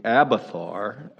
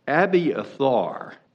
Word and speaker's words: Abiathar: I always had known it to be Abathar, or anglicized it as Abathar, but talking to Michael Thompson and commiserating with him about it, Abiathar is a better pronunciation. Abi is one, or one son, or first son, so it's one son Athar. That's where you get the Abiathar.